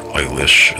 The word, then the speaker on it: Elish: The Enuma Elish, or Eilish as it's sometimes pronounced, one of the oldest creation codices on the planet, describes how these beings created humans and for varying reasons.